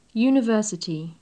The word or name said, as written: university